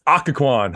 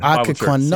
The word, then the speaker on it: Occoquan: Did I say that right? – Yeah, everybody misses it. Everybody, it's Occoquan. – Occoquan.